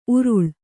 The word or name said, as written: ಉರುಲು